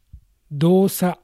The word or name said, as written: Dosa